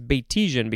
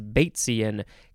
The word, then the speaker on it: Batesian: Yeah, I thought it was Batesian because that's how it's spelled, but it is Batesian, capital B A T E S I A N. It's like, um, well, it's named after a guy Bates, so that's where that's why it sounds Batesian.